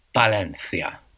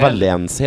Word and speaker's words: Valencia: Tydligen så är det så att V uttalas B på spanska. – Ja, som i Valencia.